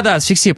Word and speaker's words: асфиксия: Но нам нужно с тобой дальше, я так понимаю, асфиксия, правильно? Да, асфиксия, правильно. Я говорю, слета читал, неправильно поставил ударение.